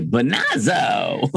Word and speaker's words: Bonazzo: And I applied for like a name pronunciation change. So I was like, I don't know who that is. My last name's actually Bonazzo.